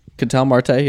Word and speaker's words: Marte: Also on top of this, did you see that Diamondbacks Marte? Did I say that right? – Ketel Marte, yep.